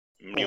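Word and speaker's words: Mjölnir: there was the Mjolnir, is that how you say it? – Mjolnir.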